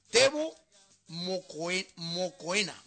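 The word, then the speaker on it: Teboho Mokoena: Tebo Mokoena. Así como se oye, ¿eh?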